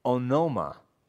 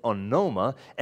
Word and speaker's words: onoma: Now, the word here, which is translated as namesake, if you look at the word in Greek, it is onoma. That's how it's pronounced. It's onoma, and it's not simply talking about a person's name, all right?